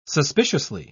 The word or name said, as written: suspiciously